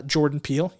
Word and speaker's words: Jordan Peele: But people were so impressed with her work on that film that, Jordan Peele, you know, or George. Yeah. Did I say that right? – Who's that? Who's Jordan Peele? – I said that right. Oh man. My brain farted for a second. – No, but you nailed it.